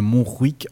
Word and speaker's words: Montjuïc: Alors, je ne sais pas si on dit comme ça. Moi, je pense qu'on dit comme ça parce qu'il y a un J. Ça s'écrit M-O-N-T-G-I-U, pardon, I-C. Donc, ça doit être Montjuïc si on la prononce à l'espagnol.